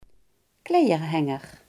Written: kleerhanger

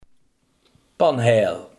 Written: panheel

